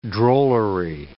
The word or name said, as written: drollery